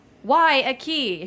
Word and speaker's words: Guayaquil: It's like Guayaquil if you're at a door, but yeah.